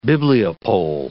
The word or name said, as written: bibliopole